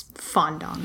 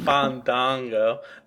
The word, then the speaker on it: Fandango: You're saying his name wrong, guys. It's Fandango. – Fandango.